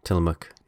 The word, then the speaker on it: Tillamook: So y'all have Tillamook ice cream and cheese up there, right? Am I saying it right? – Oh, yeah. Tillamook.